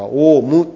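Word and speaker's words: 王蟲: で、その、こう腐った海、深いの中に虫がいるんですけれども、王様虫と漢字で書いて、なぜかオウムと読むこう巨大な虫がおります。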